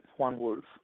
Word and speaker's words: Juan Wulff: Yep, that's right, Juan Wulff.